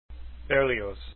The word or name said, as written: Berlioz